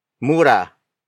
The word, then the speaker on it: mura: Pandak is spelled as P-A-N-D-A-K. It means short in stature. Another example, mura. Mura. Mura. Mura is spelled as M-U-R-A.